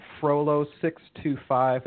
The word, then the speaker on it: Frolow: You can find me on Twitter at Frolow625, and that's spelled F-R-O-L-O-W.